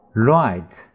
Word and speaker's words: write: write 写 wr 这个 w 不发音只发 d 那个字母 r 发音 Letter,